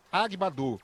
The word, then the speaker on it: Agbadou: Pronúncia Agbadou.